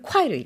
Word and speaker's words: 块: 근데 우리 이거를 콰이로 읽죠.